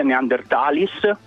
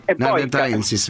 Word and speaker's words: Neanderthalensis: Non si dice Neanderthalis, ma Neanderthalensis. – Bravo, sì, scusami, hai ragione, è verissimo. Neanderthalensis, bravo.